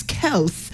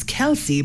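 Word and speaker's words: Kelce: Taylor Swift and Travis Kels is how he pronounces the name. I was always thought it's Kelsey, but hey.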